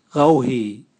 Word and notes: gauhi: Raga

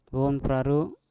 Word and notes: wom praru: mpur

